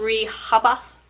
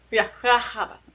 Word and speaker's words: Rehoboth: Rehoboth. That's how it's pronounced. – Everything's kind of silent. – Yeah. Rehoboth. That's it. Just, you get the H sound, and that's it.